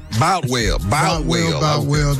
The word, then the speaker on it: Boutwell: Botwell Auditorium. Am I saying that right? Boutwell or Botwell? Boutwell.